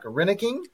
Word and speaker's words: Reinking: It's Mark Reineking.